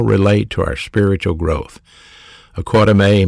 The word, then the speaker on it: oikodome: Edification is a word in the Greek New Testament pronounced oikodome. Oikodome. O-I-K-O-D-O-M-E. Oikodome. Long E on the end of it.